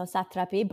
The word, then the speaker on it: Satrapi: So to give you guys a bit of background, Marjane Satrapi, am I saying that right? – I think so, Satrapi, but I think yeah, she goes by Marjane Satrapi.